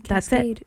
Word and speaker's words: Kaskade: K-A-S-K-A-D-E. Cascade.